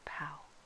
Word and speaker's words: Pau: Then Baal-Hanan, the son of Achbor, died, and Hadad became king in his place, and the name of his city was Pau, or Pau, depending on where you're from, how it's pronounced.